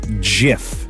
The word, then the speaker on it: GIF: He'd stated in 2013 in a conversation with the New York Times, it's a soft G pronounced GIF. End of story.